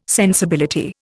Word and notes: sensibility: Indian

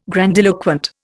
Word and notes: grandiloquent: Indian

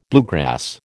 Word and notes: bluegrass: American English

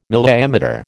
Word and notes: milliammeter: American English

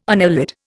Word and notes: annelid: Indian

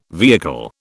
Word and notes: vehicle: British English

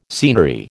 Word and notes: scenery: American English

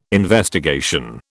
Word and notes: investigation: British English

